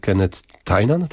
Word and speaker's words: Kenneth Tynan: Ten list pisze Kenneth Tynan, tak się wymawia? – Tak.